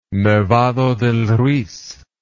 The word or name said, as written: nevado del ruiz